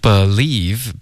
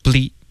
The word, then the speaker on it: believe: Okay, believe, 别发成 bleep. Okay, 这是一个音节，我们要 believe 两个音节。